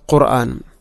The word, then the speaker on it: القرآن: ഇവിടെ ഖുർആന എന്ന പദത്തിൽ പ്രത്യേകിച്ച് നമ്മൾ ശ്രദ്ധിക്കേണ്ടത്, എപ്പോഴും നമ്മൾ പറയുന്നൊരു വാക്കാണത് ഖുർആൻ. നമ്മൾ പലപ്പോഴും ഖുർആൻ ഖുർആൻ എന്നാണ് പറയുക. അപ്പോൾ ഖുർആന ഖുർആന, ഖാഫിൻ്റെ ഉച്ചാരണമെങ്കിലും പ്രത്യേകിച്ചും ശ്രദ്ധിക്കണം. അതൊരിക്കലും കാഫിനെ കൊണ്ട് പറയരുത്.